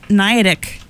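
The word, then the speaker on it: Gniadek: Krista Niadic is right here. That's spelled with a G. G-N-I-A-D-E-K.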